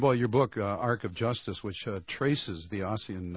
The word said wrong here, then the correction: Ossian: or actually I've been mispronouncing his name all the time. It's Ocean. – It is Ocean.